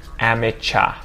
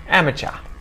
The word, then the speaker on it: amateur: And then as a little bonus here at the end, guys, sometimes words with UR at the end are pronounced as ah as well. Although a lot of the time they're gonna sound like er. And one example I could think of was amateur, amateur.